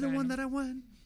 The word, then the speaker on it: You're the One That I Want: You're the one that I want.